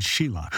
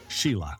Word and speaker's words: Shelagh: Shelagh, S-H-E-L-A-G-H, Shelagh, is pronounced many ways by the many who confront its Irish spelling.